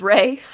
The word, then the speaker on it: Ralph: I'm still convinced his name is Ray. And you know what? He never once put in parentheses how to pronounce his name properly. – That it's Ralph.